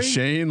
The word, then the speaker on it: Shane: His first name rhymes with Bane. – Bane? – And his last name rhymes with Owry. – Something Lowry? – Shane Lowry.